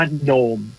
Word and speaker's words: gnome: ถ้าเกิดว่าเอาตัว G ออกไปนะครับ G แล้วก็กลายเป็น NOME จะออกเสียงว่าโนมเฉยๆนะครับไม่ได้ออกว่าจีโนมนะ